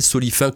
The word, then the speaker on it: Solifin: « J'aime Solifin », »,« J'aime GEM », groupe d'entraide mutuelle, Solifin, comme ça se prononce, S-O-L-I-F-I-N.